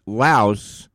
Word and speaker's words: Laos: The official language in the country of Laos is Lao, L-A-O. And if you want to do some extra research about the country of Laos, it's pronounced Laos, but it's spelled L-A-O-S.